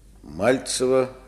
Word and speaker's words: Мальцева: Мальцева Любовь Николаевна. Я правильно делаю ударение? – Да, конечно. А разве бывает эта фамилия с другим ударением? – Да всякое бывает.